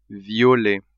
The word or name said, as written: violet